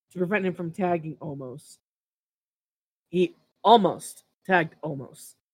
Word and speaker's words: Omos: Omos. – Did I say it right? Yeah, Omos. Yes. This was Omos. So it's like Almost. Almost.